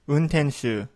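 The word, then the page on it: untenshuu: Japanese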